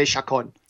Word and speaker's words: Chacon: It's a hard C. I went to high school with a guy named Ray Chacon.